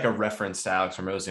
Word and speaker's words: Hormozi: Alex Hormozi.